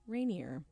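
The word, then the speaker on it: Reinur: And the biggest surprise here is that it's pronounced Rainier. For those who can't see what I'm looking at, it's R-E-I-N-U-R, Selsen. This person is Icelandic, so that's how they pronounce it.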